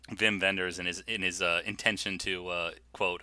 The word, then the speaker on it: Wim Wenders: but certainly has a cult following in Wim Wenders. – Mm. I was going to say this. – You can see how pretentious I'm being by pronouncing his name in the proper Germanic way.